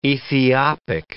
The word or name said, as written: Ethiopic